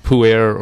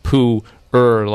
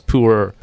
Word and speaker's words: pu'er: And then people tend to think there's a lot more going on with the pronunciation, and they tend to say like pu'er or something, uh-huh. and the way that they pronounce it is actually like pu'er, like mm-hmm. Like Winnie the Pooh, and like ergonomic, so it's pu'er.